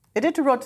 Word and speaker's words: Iditarod: Iditarod Sledders.